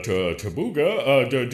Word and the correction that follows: Taguba: do we... Senator, it's Taguba. That's it. Taguba. It's only three syllables. It's not hard.